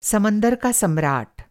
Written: समंदर

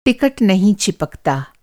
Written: चिपकना

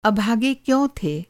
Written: अभागा

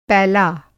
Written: पैला

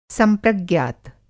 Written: संप्रज्ञात

